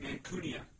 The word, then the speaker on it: Mancunia: And I think we're going to be examining the state of Union in Mancunia. Is that how you say it? – People's Republic of Mancunia.